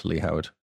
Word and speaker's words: Lee Howard: At Lee Howard.